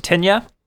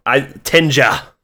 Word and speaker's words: Tinja: Is it Tinya? – Doesn't matter. – Tinja. – Tinja? – Not Tinya.